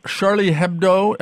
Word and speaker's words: Charlie Hebdo: So Charlie Hebdo, am I pronouncing that more or less correctly? – More or less, yes.